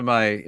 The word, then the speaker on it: Em: When I give my name at coffee shops, they think I'm saying Emma, Amy, or the letter M. And none of those Oh.